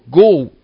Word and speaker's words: گاو: ببینید تلفظ گاو، یا ما لهجه ساوهی چی میگیم؟ گو. این تلفظ اصلی گاوه، گو.